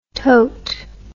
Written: toat

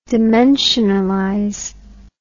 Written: dimensionalize

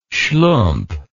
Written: schlump

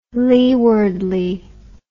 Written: leewardly

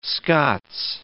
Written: Scots

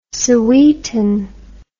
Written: sowetan

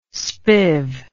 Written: spiv

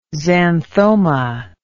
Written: xanthoma

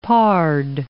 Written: pard

